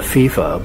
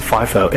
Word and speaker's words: FIFO: I call it FIFO, but some people might pronounce it FIFO, F-I-F-O.